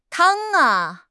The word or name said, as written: tang1